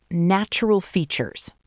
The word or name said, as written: Natural Features